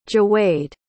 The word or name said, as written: jawaid